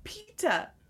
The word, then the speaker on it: Peeta: It's pita. Pita. – Oh, pita, like the bread. Okay. – Yeah, but it's spelled differently.